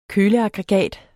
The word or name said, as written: køleaggregat